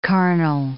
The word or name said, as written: carnal